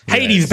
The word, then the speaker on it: Hades: Is it pronounced Hardy? Hades? – Hades? Hades? Hades? – Hades, baby.